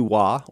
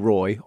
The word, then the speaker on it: Roi: The play is Ubu Wa, or Ubu Roy, R-O-I, and we're doing a translation by a guy out of Canada named David Copeland.